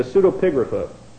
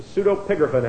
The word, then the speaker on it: pseudepigrapha: The proper pronunciation of that is the pseudepigrapha. The pseudepigrapha hasn't got a thing in the world to do with swine or sows or boars.